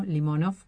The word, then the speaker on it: Limonov: De la sintonía de Limonov, que vamos a poner el acento en la primera O, Limonov, porque no es francés.